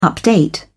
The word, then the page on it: update: British English